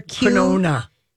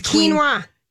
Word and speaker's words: quinoa: Quinona. – Quinona. – Yeah. What is it? How do you pronounce it? – It's quinoa. – Quinoa, Q-U-I-N-O-A. – Yeah. – Makes no sense.